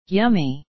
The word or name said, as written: yummy